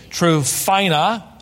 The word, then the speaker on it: Tryphena: Verse 12, Paul greets Tryphena, is how you would pronounce it, and Tryphosa.